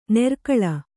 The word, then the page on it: ನೆರ್ಕಳ: nerkaḷa